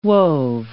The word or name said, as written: wove